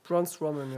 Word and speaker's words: Braun Strowman: Ähm, da gibt es auch tausend andere Beispiele, zum Beispiel dieses, diese äh, Sache ähm, Braun Strowman, oder wie, wie spricht man den aus? Braun Strowman, ja.